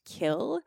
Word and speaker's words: kiln: One thing that I found, which probably has no relevance, but I thought I would mention it, is that kiln used to be pronounced kill. So apparently you would know about this much better than I would, Sam, but there's this, I guess, movement in language or this tendency for if the original word had an unpronounced letter, like the way that kiln was initially pronounced was kill. And so there's that mm-hmm. silent N at the end of it.